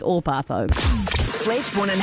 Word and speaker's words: Orpah: Her real name, so Oprah is spelt O-P-R-A-H. Her real name is O-R-P-A-H. Orpah? Orpah Gayle Winfrey?